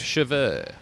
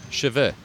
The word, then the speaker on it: cheveux: How do you say hair in French? – Cheveux. – What? – Cheveux. – The cheese? – That's chèvre.